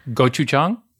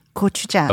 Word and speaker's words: gochujang: First of all, I'm going to have to teach you how to pronounce I didn't pronounce it correctly. Gochujang? Gochujang? No? – It's closer. It's better. Gochujang.